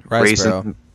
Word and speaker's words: Riseborough: Riseborough.